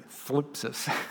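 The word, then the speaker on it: phlipsis (θλῖψις): Now, the Greek word here is for tribulation is the word phlipsis. I said that correctly, phlipsis.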